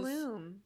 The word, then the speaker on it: Blum: Most people say Blum rhymes with plum instead of Bloom. – Bloom rhymes with plume, yeah.